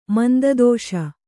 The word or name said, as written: ಮಂದದೋಷ